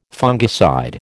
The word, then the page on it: fungicide: American English